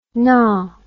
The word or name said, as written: naw